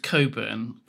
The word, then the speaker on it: Cockburn: I mean, there's Cockburn is pronounced Coburn quite often because obviously Cockburn.